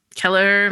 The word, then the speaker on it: Kelleher: Keller, am I saying that correctly? He's yeah.